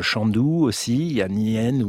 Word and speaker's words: Nien: Je ne sais pas comment on prononce, Nien, N-I-E-N, ah oui, Nien.